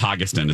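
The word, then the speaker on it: Hogestyn: I think it's Hogeston is how you pronounce his last name.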